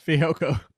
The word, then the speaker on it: Fehoko: How do I pronounce that? – Fehoko. Yeah. Fehoko.